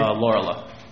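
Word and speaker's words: Laurila: Lorela.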